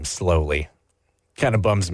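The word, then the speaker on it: Sloly: Like it's spelled like slowly? – No W, but otherwise, yeah. – Slowly. – Slowly. It's pronounced slowly.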